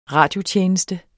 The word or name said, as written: radiotjeneste